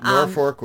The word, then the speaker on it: Norfolk: Right, Norfolk without an R. – Yeah, it's even though it's Norfolk, right. – It's pronounced Norfolk, especially if you're Bob Maxson, I guess. Yeah. I don't know why that is. – Just think that's funny. – Maybe if you're a local, that's what it is, and I'm revealing myself to not be a local.